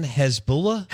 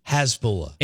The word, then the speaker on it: Hasbulla: Hezbollah. – Hasbulla.